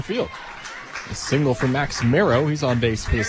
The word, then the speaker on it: Mierau: Back to the top of the order for Max Mero. Mero is spelled M-I-E-R-A-U. Talking to his dad earlier, he said, basically no one gets it right. You would think it'd be Miro, but it's marrow, like bone marrow.